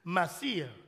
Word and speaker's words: maaser: The word is spelled M-A-A-S-E-R. That's the Hebrew word for it, Masir. M-A-A-S-E-R.